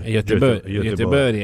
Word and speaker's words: Göteborg: Göteborg è come, vabbè, Edberry, Stromberry, perché la G è una I.